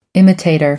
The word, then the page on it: imitator: American English